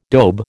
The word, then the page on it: dobe: American English